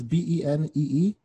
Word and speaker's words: BENEE: This artist is named Benny, I believe is how you pronounce it. It's Benee.